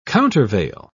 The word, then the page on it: countervail: British English